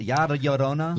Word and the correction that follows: La Llorona: La Llorona. – I can't. – Chavez. – He is directing. – It's only a Y sound if there's two L's together, right? – La Llorona.